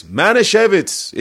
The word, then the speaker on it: Manischewitz: He goes like this, Manischewitz. It's Manischewitz. – You're like, but there's no V in there.